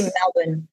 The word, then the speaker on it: Melbourne: Sorry, I should say Melbourne because they don't pronounce the R.